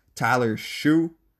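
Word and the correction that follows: Shough: It is pronounced Tyler Shuck, not Tyler Shue.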